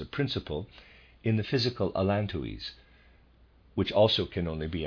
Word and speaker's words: allantois: Readers aside, I'm pronouncing the word A-L-L-A-N-T-O-I-S as allantois. End of readers aside.